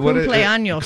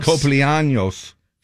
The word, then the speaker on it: cumpleaños: How do you say, is it Feliz Copeñano? – Cumpleaños. – Cumpleaños.